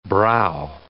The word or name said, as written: brow